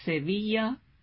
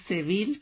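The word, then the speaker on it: Sevilla: یکی از اون پایتخت ها شهر اشبلیه یا سویا به اسپانیولی و یا سویل که به گوش همتون آشناست تلفظ فرانسش، این شهر هست که دو تا بحث اصلی داره.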